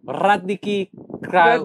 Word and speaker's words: Kragujevac: Sa Radnički Kragujevac.